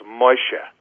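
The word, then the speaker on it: Moshe (משה): Actually, it's pronounced Moshe, but the English version is Moses.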